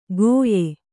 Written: ಗೋಯೆ

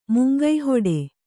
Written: ಮಂಕುಹಿಡಿ

